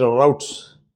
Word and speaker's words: route: Now, there are two routes, or if you want the American pronunciation, routes.